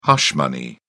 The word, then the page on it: hush money: British English